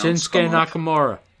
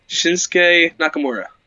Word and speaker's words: Shinsuke Nakamura: Shinsuke Nakamura. Shinsuke I just get it with Nakamura. A mouthful of banana. You got this. – Shinsuke Nakamura.